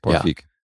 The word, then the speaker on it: Paul Feig: Regisseur Paul Feig wird da ausgesprochen, oder? – Richtig, Paul Feig.